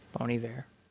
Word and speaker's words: Bon Iver: And then I did Lost in the World by Kanye West and Bon Iver. – Bon Iver. – Iver? – Bon Iver. That's how you say it.